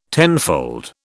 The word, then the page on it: tenfold: British English